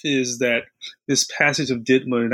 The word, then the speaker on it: DIDMA: So, in 1980, Congress passes a law called, and it's pronounced sort of DIDMA. That's the shorthand for it.